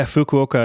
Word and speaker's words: Fukuoka: Yeah, Fukuoka, I think, is pretty Fukuoka.